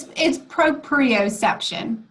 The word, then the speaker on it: proprioception: And those are those two senses, the vestibular and the proprioception, something like that, right? – Yes. Yes. Close enough. – Yeah. Okay. So you can say it the right way. What is the right? – It's proprioception.